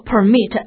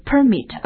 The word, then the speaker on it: permit: 所以 permit 动词读作 permit，名词是 permit。